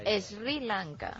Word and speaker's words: Sri Lanka: Dice que había leído Martín algo sobre Sri Lanka y sí, que pronuncia algo así como Sri Lanka. Y que no, que es tal cual, Sri Lanka.